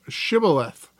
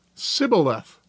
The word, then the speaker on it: Shibboleth: If he said no, then they would say to him, "Say now Shibboleth." But he would say, "Sibboleth," for he could not pronounce it correctly.